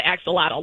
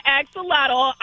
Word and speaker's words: axolotl: There's four syllables, so axolotl.